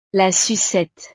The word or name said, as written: la sucette